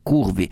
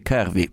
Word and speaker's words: curvy: Hanno fatto una selezione tra le cicciottelle, chiamiamole così, anche perché il termine, come avete sentito, curvi, che in realtà si dovrebbe pronunciare curvy, però dicono tutti curvi, e curvi rimarrà per tutti noi, è qualcosa che rappresenta un'idea molto incerta di quello che è la donna formosa.